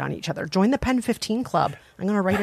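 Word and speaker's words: PEN15: Pen 15 is sort of the proper way to pronounce that title.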